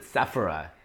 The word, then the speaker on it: Sephora: Is it pronounced Sephora or is it Sephora? – So it's Sephora.